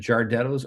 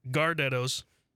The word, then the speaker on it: Gardetto's: Am I saying that right? Would you say that Giardetto's or Gardetto's? – Gardetto's.